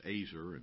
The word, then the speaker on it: ezer: spelled E-Z-E-R. It's pronounced Azer instead of Ezer, as you would think it is.